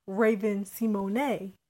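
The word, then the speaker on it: Raven-Symoné: Versus like if you look at like Raven Simone. That's how you pronounce her name for real. – It always been like that? – Yeah, that's her actual name. – I'm saying it always been like that is hilarious. – Everybody just been pronouncing her name wrong this whole time. – Oh wow, that's disrespectful. – Yeah. It's French apparently.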